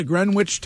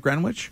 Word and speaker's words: Greenwich: The Greenwich Times? – Yep. – I'm just doing that because there's one texter who gets really annoyed when I pronounce the W. – It's silent.